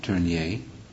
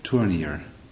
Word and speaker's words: Tournier: I guess you pronounce his name Tournier, like Tournier.